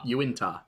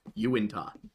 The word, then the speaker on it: Uintah: Uintah, I believe, is the correct pronunciation. That's what I was told. Uintah.